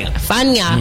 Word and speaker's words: Fania: The Fania, am I saying that right? – Mm-hmm. Fania.